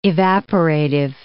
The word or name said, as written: evaporative